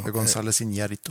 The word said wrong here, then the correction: Iñárritu: eh, Iñárritu.